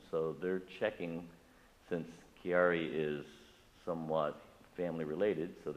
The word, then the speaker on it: Chiari: William has Carrick, am I saying that right? – Chiari, right, it's a Chiari tumor, and they've been working with that for several years, so you probably remember that.